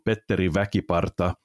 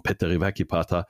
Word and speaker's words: Petteri Väkiparta: Die aufmerksamen Hörer wissen, dass ich Sympathisant von Bad Nauheim bin, aber tatsächlich der Trainer der Lausitzer Füchse, Petteri Väkiparta, oder auf Finnisch ausgesprochen, Petteri Väkiparta, aber ich habe es so halb deutsch ausgesprochen, Petteri Väkiparta, ist Trainer bei den Lausitzer Füchsen, ein ganz alter Freund von mir.